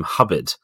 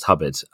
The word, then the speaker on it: Hobart: It's probably worth saying that actually the library, the core collection of the library, was formed not by the Hobarts, or actually, as you're supposed to pronounce the name, Hubbard, which is quite interesting. It's like their neighbours, the Chumleys, over at Houghton Hall, and like many other grand families, the pronunciation of the name is very different to the spelling. So they spelt Hobart, it's pronounced Hubbard.